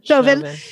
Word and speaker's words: Chauvin: How do you say his name? – Chauvin.